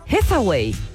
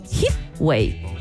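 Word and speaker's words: Heatwave: Yo le decía Hathaway. Y se llama Hathaway. Hit de calor, como una ola de calor.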